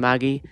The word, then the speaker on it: Magi: It's just how the Japanese pronounce it. So you're correct with Magi. Yeah. – Oh. I mean, and season one is my favorite season too from Maggie.